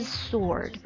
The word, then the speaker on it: sword: 这个剑这个单词 W 是不发音的，直接是 sword，